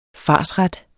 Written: farsret

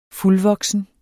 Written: fuldvoksen

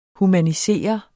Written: humaniserende

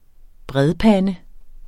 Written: bredpande

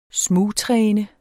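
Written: SMUGTRÆNET

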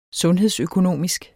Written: sundhedsøkonomisk

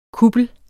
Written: kuppel

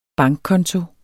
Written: bankkonto

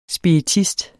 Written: spiritist

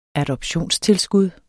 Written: adoptionstilskud

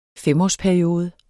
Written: femårsperiode

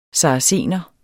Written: saracener

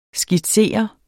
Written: skitsere

